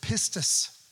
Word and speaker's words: pistis: The word is pistis.